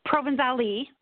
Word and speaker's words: Provenzale: Provenzale, you say the E.